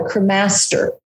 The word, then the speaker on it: cremaster: It's called a cremaster. Cremaster. You gotta get that syllable in the right spot.